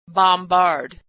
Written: bombard

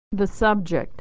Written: the subject

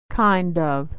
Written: kind of

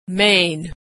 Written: Maine

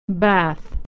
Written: bath

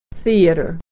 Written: theater

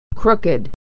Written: crooked

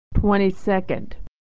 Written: 22nd

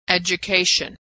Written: education

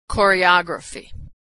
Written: choreography

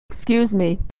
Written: excuse me

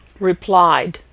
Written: replied

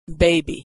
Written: baby

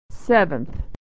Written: seventh

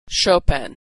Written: Chopin

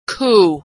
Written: coup

